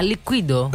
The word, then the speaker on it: Liquido: tedeschi Liquido. Prova a dirlo tu, com'è che si chiamano questi? – Liquido. – No, cadi sulla I l'accento liqui. – Ah, Liquido.